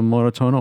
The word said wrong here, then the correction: Moratones: oder habe ich das nicht richtig gesagt? – Also Moratones ist der spanische Titel des Filmes und der heißt auf Deutsch blaue Flecken oder Hämatome oder sowas.